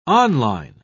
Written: on-line

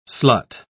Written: slut

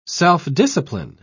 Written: self-discipline

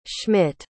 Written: Schmidt

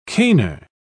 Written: caner

